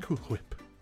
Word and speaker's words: Cool Whip: No, I say it like that. Cool Whip. Pronounce the H.